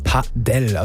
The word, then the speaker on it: Padel: Und ich sag's jetzt nochmal, die richtige Aussprache ist Padel, also Betonung auf der ersten Silbe.